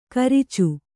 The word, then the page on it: ಕರಿಚು: kannada